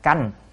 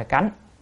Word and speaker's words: canh: Nhưng mà tới khi coi kỹ lại đó thì cái chữ này phải đọc là canh chứ không phải cánh.